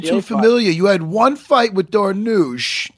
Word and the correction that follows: Darush: Did I pronounce it? – Darush.